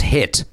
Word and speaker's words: HIIT: So this was a typo. It's still pronounced HIT, cardio HIT.